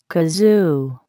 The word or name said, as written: kazoo